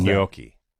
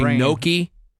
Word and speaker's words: gnocchi: People say gnocchi. – Oh. – It's not gnocchi. – Yeah. – Gnocchi. It's like gnocchi. – That's what the G is for. – Come over. Gnocchi. – Okay, say it correctly one more time. – Gnocchi. – Gnocchi. – Gnocchi. – It's closer to gnocchi.